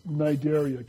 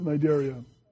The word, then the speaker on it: Cnidaria: And it starts with a C, but it's a silent C. And so you pronounce this Cnidaria. Can you guys pronounce it, Cnidaria?